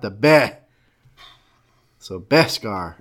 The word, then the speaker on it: Beskar: Well, make sure you say that right because Dave Mann, you know, is. – Beh. – Does not Scar. Like how he say Beh. Scar. – Beskar. He nailed us on that.